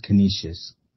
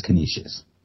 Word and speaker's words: Canisius: It's, I think it's just Canisius. – Canisius? Canisius? Okay. yeah, yeah. Um, Chris Bevins, our our um, sports editor, kind of um, pronounced it for me when we were talking about it. It's Canisius.